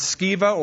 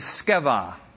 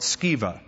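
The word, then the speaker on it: Sceva: And also there were seven sons of, now you can pronounce it Skiva or Siva, or the Greek is Skeva. Doesn't matter, we anglicize it, so call it Skiva.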